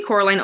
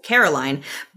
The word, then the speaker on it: Coraline: While movie Coraline only mildly corrects people when they mispronounce her name as Caroline, book Coraline gets much more incensed over the mispronunciations.